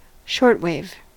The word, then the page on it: shortwave: American English